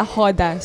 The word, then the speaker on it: roda: Yeah, to if it's your first time to look at a capoeira, we call it a hoda. So it's spelled as R-O-D-A.